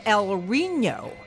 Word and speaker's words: El Reno: The city in Oklahoma is El Reno, and I'm going to pronounce that the Spanish way, even though it doesn't have the little hoopla over the N.